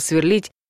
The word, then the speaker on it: сверлит: В настоящем времени ударение в глаголах, похожих на «звонить», наш случай, падает всегда на последний слог. «Сверлю», «сверлишь», «сверлит», «сверлим», «сверлите», «сверлят». А в прошедшем — на последнюю гласную основу. «Сверлил», «сверлила», «сверлили» и так далее.